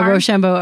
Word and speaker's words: Roshambo: Yeah, Roshambo Artfarm.